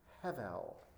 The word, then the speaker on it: Hevel: His name is Hevel. That's how you say it in Hebrew, Hevel, which is the word for breath.